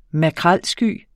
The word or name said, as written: makrelsky